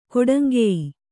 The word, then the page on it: ಕೊಡಂಗೆಯ್: koḍaŋgey